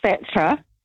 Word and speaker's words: Phaedra: That's actually pronounced Phaedra, and it's Phaedra.